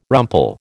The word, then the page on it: rumple: American English